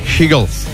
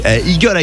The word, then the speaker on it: Hegel: Alors, faut bien prononcer Hegel. Parce que si tu dis Eagle, I tient rien.